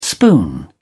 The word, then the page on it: spoon: British English